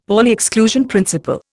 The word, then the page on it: Pauli exclusion principle: Indian